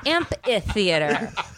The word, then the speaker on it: amphitheater: Apparently, it's amphitheater. I was adding an extra L.